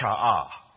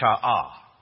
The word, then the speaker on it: sha'ah: It is the word, and the best way to do it is just to transliterate, is to pronounce it, is to write it like we pronounce it, sha'ah. S-H-A, and I put a hyphen with it, ah, sha'ah. Again, sounds nothing like fear.